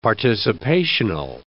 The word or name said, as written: participational